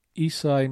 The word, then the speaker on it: Esai: Isai Morales. – Beautiful. I think that's correct. – His first name is spelled E-S-A-I, but I'm not familiar with that as a first name, so.